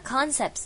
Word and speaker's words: concepts: 好， complex concepts, 那 concepts 这个重音是在的的 concepts 在最前面哦，所以不要念到第二个音节了，然后记得它是有加复数的，所以是要念次次次 concepts。